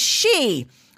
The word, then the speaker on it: Xi: Never mind that the letters XI is pronounced Qi and the name Xi spoken as she.